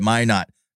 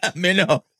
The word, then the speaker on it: Minot: You know, it's the States when it's phonetically, when you would pronounce it phonetically, you know, Minot. M-I-N-O-T, Minot. Yep. We're not as cool as they are in Canada. We're not doing any French accents. It's just Minot.